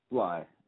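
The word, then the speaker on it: Bligh: Bly.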